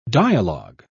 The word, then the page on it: dialog: British English